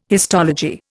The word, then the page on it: histology: Indian